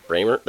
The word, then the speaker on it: Brehmer: Denali Brehm? How do you say that last name? – Bramer? – That's how you say Bramer? – Yeah.